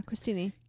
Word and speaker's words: crostini: C-R-O. – Yeah. – Did I say it right? – Yeah, Crostini.